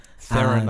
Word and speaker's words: Theron: Theron, I believe it's pronounced. Theron.